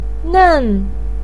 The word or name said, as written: nen4